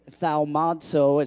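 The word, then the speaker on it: thaumazo (θαυμάζω): The Greek word for marvel, which is thaumazo, is how you pronounce it. It's not really how it's spelled.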